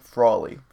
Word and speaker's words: Frawley: And he has a direct Jim Farrelly. I can't pronounce that. – That is going to be a Jim Frawley.